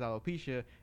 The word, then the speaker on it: alopecia: Yeah, I mean, not everybody knew that she had alopecia. I believe it's how you pronounce it.